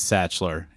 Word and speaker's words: Sachtler: Is it Satchler? – It is Satchler, yeah. I guess that's how you pronounce that.